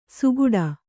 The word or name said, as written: ಸುಗುಡ